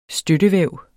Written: støttevæv

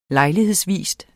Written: lejlighedsvis

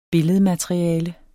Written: billedmateriale

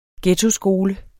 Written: ghettoskole

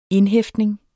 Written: indhæftning

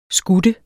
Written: skutte

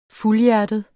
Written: fuldhjertet

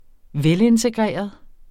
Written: velintegreret

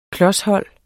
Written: klos

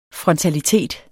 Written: frontalitet

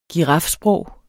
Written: girafsprog

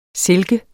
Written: silke

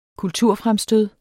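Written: kulturfremstød

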